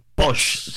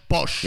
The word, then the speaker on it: pox: Posh se pronuncia. Posh.